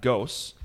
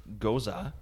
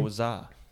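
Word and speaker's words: Gose: Goza? It's not Ghosts. It's Goza. – What's the difference? – I don't know. It's in parentheses. Pronunciation. It says Goza.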